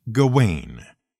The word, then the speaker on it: Gawain: So, when confronted with a Welsh name spelled G-A-W-A-I-N, the one thing you can be absolutely sure of is that it isn't pronounced Gawain. It's much closer to Gawain.